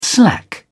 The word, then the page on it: slack off: British English